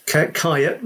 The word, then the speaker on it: Kyiet: Luckily, I've got a unique surname, so it's Kyiet,